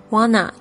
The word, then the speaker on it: wanna: 那这个 want to 老师呢，第二次录音呢是念成 wanna，就是想要做什么事情 wanna。